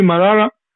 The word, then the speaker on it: Marara: Marara.